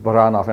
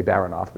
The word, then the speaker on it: Baranov: It's Baranov in Russian, but we say Baranov because we have to mispronounce usually what we get from other countries.